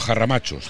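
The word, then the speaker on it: Harramachos: ¿Arramachos o jarramachos? – Jarramachos se dice aquí en Ávila, se escribe con H, pero jarramachos.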